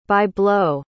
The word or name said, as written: by-blow